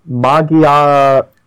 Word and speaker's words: Magearna: And we got our representative, our first legendary that appeared for Gen 7 is coming to the TCG, Magearna. I said that right, right? – Yes. It's pronounced so. Japanese Magearna. – Such a show-off. – Magearna. So it's Magearna.